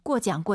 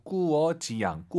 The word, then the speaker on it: 过奖: 꾸어장, 꾸어장. – 이렇게 말이죠. 꾸어장, 꾸어장. 발음을 잘하셔야 돼요. 잘못하면 그 대한민국의 은어가 될 수 있어요. 꾸어입니다. 네, 꾸가 아니라 꾸어지양, 꾸어장.